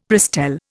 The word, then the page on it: Prestel: Indian